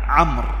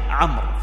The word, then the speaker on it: عمرو: أنبه هنا أيها الإخوة الأفاضل عن عمرو، لا تقل عمرو عمرو ليست صواب، وإنما هي عمرو، فلان اسمه عمرو، لا هذه غير صحيحة. هذه الواو بارك الله فيكم ما تنطق بالطريقة الأولى، وإنما يقال عمرو، وإنما يقال عمرو وليس عمرو، هذه ليست بصواب.